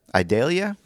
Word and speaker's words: Idalia: That was Idalia. Is that how you pronounce it? – Oh, yeah.